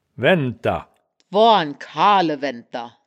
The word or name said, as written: waenndter